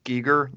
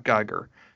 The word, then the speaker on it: Giger: it's pronounced Giger, not Geiger, as far as the artist, H.R. Giger, not H.R. Geiger, which I have been, I'm sure, pronouncing for years. Mispronounced for years.